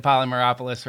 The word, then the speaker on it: Polymeropoulos: I did get Polymeropoulos right again.